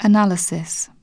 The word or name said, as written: analysis